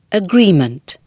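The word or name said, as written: agreement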